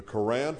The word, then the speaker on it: Quran: The Quran from Allah, and that's the way you pronounce it.